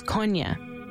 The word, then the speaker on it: Kony: The proper pronunciation is Konya.